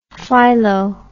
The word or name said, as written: phylo